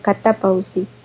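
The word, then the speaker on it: catapausis (κατάπαυσις): Ahí la palabra para reposo es catapulto. A ver, déjeme la leo, porque catapausis, lleva tilde en la segunda, catapausis.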